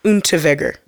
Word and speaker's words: Unterweger: I think the W is supposed to be pronounced as a V. – Oh, Unterweger.